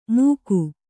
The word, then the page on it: ಮೂಕು: Kannada